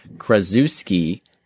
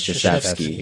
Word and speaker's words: Krzyzewski: So he says, a little while back, pronounced Duke Basketball's head coach, Coach K's name as Krasuski, but it's actually Krzyzewski.